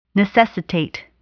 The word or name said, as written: necessitate